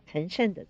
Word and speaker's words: 岑参: 第三十五首山房春事二首，其中的第二首岑参的作品。这个岑参的参字呢，因为这个参这个字有很多不同的读音，也可以念参加。的参，所以有人把他的名字念成岑参啊，这个我们不知道他父亲给他起名字的时候用的是什么意思，应该怎么样读。